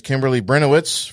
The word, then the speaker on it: Kimberly Brenowitz: And we are going to get started this morning with Miss Kimberly Brenowitz from Pause for Life.